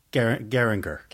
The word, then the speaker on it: Geiranger: Geiranger.